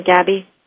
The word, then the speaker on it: Gabbay: Pamela, do I say your last name Gabbay? – It's Gabbay.